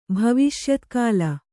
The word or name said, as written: ಭವಿಷ್ಯತ್ಕಾಲ